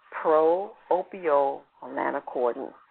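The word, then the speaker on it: pro-opiomelanocortin: So one large protein molecule that is found in the brain is known as, now here's another word for your avatar's vocabulary, pro-opio-melanocortin. I'll say it again, pro-opio-melanocortin. Let's spell it. It's P-R-O-O-P-I-O-M-E-L-A-N-O-C-O-R-T-I-N.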